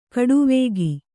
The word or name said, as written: ಖಡಾವಿಗೆ